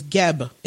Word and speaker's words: Geb: The next astrology is Geb. Is that how you say it? G-E-B for Egyptian? – Yes.